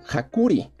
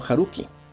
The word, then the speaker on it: Haruki: La primera, que siempre lo había pronunciado Hakuri, no Haruki.